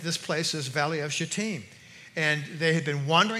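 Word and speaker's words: Shittim: It's actually pronounced Shatim, you know. Yeah, at least that's the way the Baptists pronounce it. And but it is. I think that's the actual pronunciation of it.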